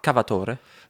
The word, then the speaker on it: Cavatore: Also sagt man auch Cavatore? – Nee, man sagt Cavator, also man spricht französisch aus. – Ah, okay. – Also italienischer Name, der französisch ausgesprochen wird.